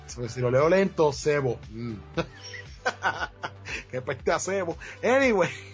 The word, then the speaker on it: Zebo: Sebo, así que se pronuncia. Sebo Temple. Es con Z. – Sebo, entonces. Sí. Z-E-B-O.